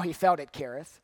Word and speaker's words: Cherith: Spelled Cherith, pronounced Kereth, lived by the brook Kereth that is east of the Jordan.